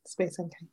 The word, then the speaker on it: Space NK: You've been uh, styling music artists um, for press looks and performances, and ad campaigns for um, Browns and Space NK. Is that how I pronounce it? Yeah, Space NK.